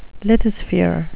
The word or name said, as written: lithosphere